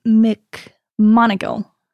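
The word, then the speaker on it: McMonagle: Okay, so moving kind of forward in time, I want to look at 1984 at Army veteran Joseph McMonagall. Am I pronouncing that right? – Yeah, like from Harry Potter.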